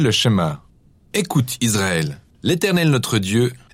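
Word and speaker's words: schéma (שמע): Le premier mot du schéma est « écouter » ou « entendre », prononcé « schéma » en hébreu.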